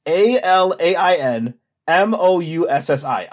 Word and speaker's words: Alain Moussi: It's A L A I N M O U S S I. Alain Moussi.